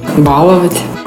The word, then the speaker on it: баловать: В настоящий момент существует лишь один правильный произносительный вариант этого слова. Как и в большинстве глаголов на «ова» – целовать, рисовать, ударение падает на последний слог – «баловать». Интересно, что на корень ударение переходит только в слове «баловень». В остальных же случаях оно остается за пределами корня. «Балую», «балуешь», «избалованный».